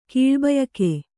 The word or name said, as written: ಕೀಳ್ಬಯಕೆ